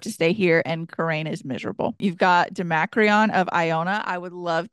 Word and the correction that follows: Domacridhan: Demacreon has two D's. Demacreon. – Where? – It's Domacridhan. I saw her with, I think it was Tiffany D.